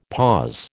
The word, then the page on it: pause: American English